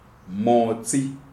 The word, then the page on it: Motsi: Shona